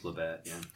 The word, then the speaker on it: Labatt: Labatt. – I think it's Labatt, Canadian company.